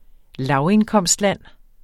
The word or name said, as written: lavindkomstland